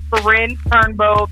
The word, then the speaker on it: Karinne Turnbow: So Facebook is going to be my first and last name, Karinne Turnbow. That is spelled K-R-I-N-N-E-T-U-R-N-B-O-W.